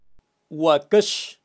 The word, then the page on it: wakax: Kaqchikel